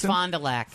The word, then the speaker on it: Fond du Lac: Fond du Lac. Is that how you pronounce it? Fond du Lac, Wisconsin? – I think it's Fond du Lac.